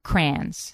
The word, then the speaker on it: crayon: I call them crayons now, but I grew up calling them crayons, C-R-A-N, like cranberry. Crayon, one syllable.